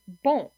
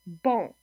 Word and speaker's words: bon: So this would be a non-nasalized vowel in the word beau, beau, and then nasalized would be bon, bon. So in a nasalized vowel, nasal vowel, some of the air actually comes through your nose when you pronounce the vowel, not just through your mouth.